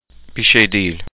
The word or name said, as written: bir sey degil